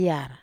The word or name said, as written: yar